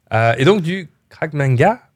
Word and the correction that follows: Krav Maga: désolé, j'ai mal prononcé, je suis sûr. Ah, ça s'appelle le Krav Maga.